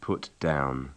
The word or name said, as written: put down